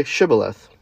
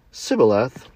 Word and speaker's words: Shibboleth: If he replied, "No," they said, All right, say, and this is interesting, "If you're an Ephraimite, say Shibboleth." If he said Sibboleth, because he could not pronounce the word correctly, they seized him and killed him at the fords of the Jordan.